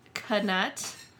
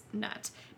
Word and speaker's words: Knut: Personally, it should be pronounced nut, because who in their right mind would pronounce the K, but JD does say knut.